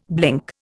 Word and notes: blink: Indian

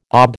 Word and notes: OB: American English

